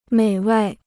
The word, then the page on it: 美味: měi wèi